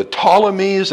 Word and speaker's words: Ptolemies: So, the Syrian wars, the overview of the kings, basically it took a while to settle, but the struggle came down to the Ptolemies, that's a silent P by most reckoning, and the Seleucus Empire, what is now Turkey in that area, as I said already, the north and the south.